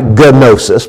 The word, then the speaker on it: gnosis: It's spelled G-N-O-S-I-S. Like gnosis, but the G is silent, so it's gnosis, G-N-O-S. But the word for knowledge here in verse 9 is epignosis. There's an E-P-I on the front of gnosis.